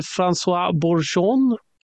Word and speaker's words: François Bourgeon: Den internationella gick till François Bourgeon. Mm. Perfekt franskt uttal mm -hmm.